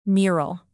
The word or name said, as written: mural